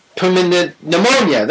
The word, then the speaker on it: pneumonia: Pneumonia, that's it. The P is silent.